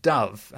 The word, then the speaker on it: Dove: And we have Caroline Dove, and I know I'm pronouncing that correctly because she said, dove like the bird, not dove into the water.